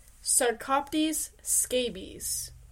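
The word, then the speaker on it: Sarcoptes scabiei: It's pronounced Sarcoptes scabies.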